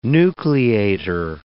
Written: nucleator

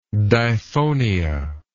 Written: diphonia

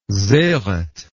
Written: sereth

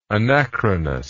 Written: anachronously

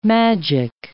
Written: magic

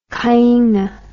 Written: kainga

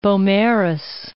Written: Beaumaris

